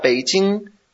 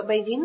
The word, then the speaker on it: Beijing: Un momento, ¿Beijing no es con B? Se pronuncia con P. – Beijing. – Beijing. Pero muy, muy suave, ¿no? – Ya sabes que si fuera con P sería Beijing, Beijing, pero no es así. Es Beijing, Beijing.